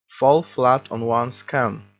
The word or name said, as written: fall flat on one's can